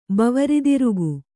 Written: ಬವರಿದಿರುಗು

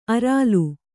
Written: ಅರಾಲು

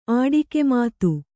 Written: ಆಡಿಕೆಮಾತು

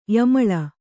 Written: ಯಮಲಿ

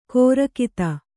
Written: ಖುರಗತಿ

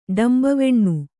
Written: ಡಂಬವೆಣ್ಣು

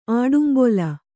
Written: ಆಡುಂಬೊಲ

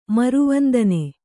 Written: ಮರುವಂದನೆ

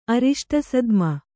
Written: ಅರಿಷ್ಟಸದ್ಮ

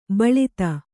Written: ಬಿಳ್ತು

